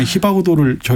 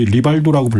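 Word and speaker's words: Rivaldo: 예전에 히바구도를 저희 리발도라고 불렀었는데 아. 네, 뭐 알이 히읗 발음 된다고 하면서 그러니까 네.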